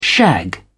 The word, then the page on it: shag: British English